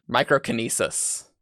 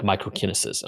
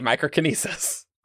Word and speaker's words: microkinesis: I always want to put this stress on the I, like microkinesis. And I'm like, that's not. – I mean, I don't know. Brandon pronounces things weird, but I say microkinesis.